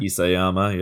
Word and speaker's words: Isayama: I was gonna say, do you think that Isayama, or is that how you say his name? – Isayama, yeah.